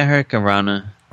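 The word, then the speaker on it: Hurricanrana: Yeah, Hurricane Rana.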